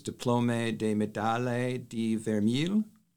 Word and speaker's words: Diplôme de Médaille de Vermeil: And then there's also a French, there's a French award, and you can correct my pronunciation, which is Diplôme de Médaille de Vermeil. – Perfect.